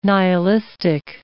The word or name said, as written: nihilistic